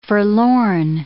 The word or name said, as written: forlorn